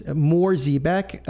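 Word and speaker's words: Mohr Siebeck: It's Mohr Ziebeck, MohrSiebeck, Mohr Ziebeck. That's the German pronunciation.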